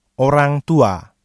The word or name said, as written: orang tua